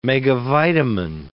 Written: megavitamin

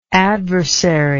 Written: adversariness

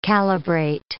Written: calibrate

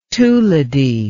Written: tuladi

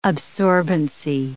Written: absorbency

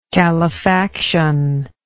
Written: calefaction

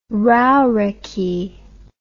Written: rauriki

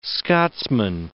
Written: Scotsman

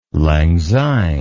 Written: lang syne

